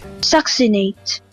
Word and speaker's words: succinate: or it has the other one, which I actually don't know how to pronounce. So I found a YouTube link to help us pronounce it. – Okay. – Succinate.